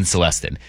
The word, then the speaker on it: Celestin: like a Celestin, who his Montez. – Name has been pronounced 3 different ways, but I think we've settled on Felton Celestin.